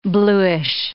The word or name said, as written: bluish